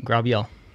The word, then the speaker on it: Grabiel: Yep, Grabiel.